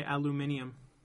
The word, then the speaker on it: aluminium: Or like the British say aluminium.